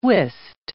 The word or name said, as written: wist